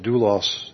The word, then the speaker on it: doulos: That word servant, the Greek word is, I'm not sure I can say it right, but doulos is how it's pronounced.